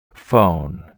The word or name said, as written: phone